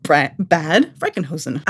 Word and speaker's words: Bad Frankenhausen: The 15th century square called Leaning Tower of Sörhausen and the 14th century bell tower in the town of Bad Frankenhausen. – And it's called Bad Frankenhausen. – I am not challenging your pronunciation.